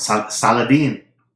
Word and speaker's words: Saladin: Saladin.